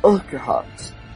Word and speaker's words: Urquhart: Frank Urquhart, sei lá, como é que fala essa porra, ou PCB? – Urquhart.